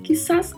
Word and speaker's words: quizás: E muita atenção aqui com a pronúncia, porque a letra Z, a letra Z, não tem som de Z no espanhol, ela tem som de S. Então falamos quizás.